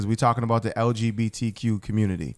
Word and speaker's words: LGBTQ: Well, I mean, this focusing on the actual issue that his comments actually offended the LBGTQ yep, exactly. if did I say that right? – LGBTQ. LGBTQ. Yeah.